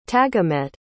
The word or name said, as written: tagamet